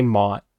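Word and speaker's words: Motte: Moti, that's how you say his name? Am I just losing my fucking mind? I would say Mott.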